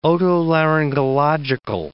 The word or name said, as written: otolaryngological